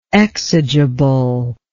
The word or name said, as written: exigible